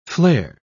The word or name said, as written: flare